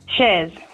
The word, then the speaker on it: Chers: Shers or Shers? – Shers. – Shers, because the girls here have written your name as C-H-E-R-S. Is that how you spell That's it? – That's how you spell it.